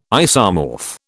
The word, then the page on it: isomorph: British English